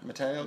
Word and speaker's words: Matteo: Instagram, Mateo Cassano Icons. – How do you spell it? – M-A-T-T-E-O. – Uh-huh. – C-A-S-S-A-N-O. – Uh-huh. Is that your real name, Cassano? – Mateo Cassano, yeah.